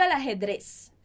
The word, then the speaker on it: al ajedrez: Só um cuidado com essa pronúncia. Al ajedrez. Ok? Porque a próxima palavrinha começa com uma vogal. Então, você junta o L com o A.